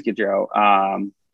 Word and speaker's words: Goodrow: There are rumors that Goodrow could, Goodrow. It is Goodrow. It feels like it should be pronounced differently.